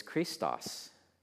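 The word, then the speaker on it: Christos (χρηστός): If you're pronouncing this the way a Greek speaker in the first century would have pronounced it, it would be something like, the Lord is Christos. Christos means good, kind. Christos is the anointed one, the Messiah. Spelled slightly differently, but pretty much pronounced the same.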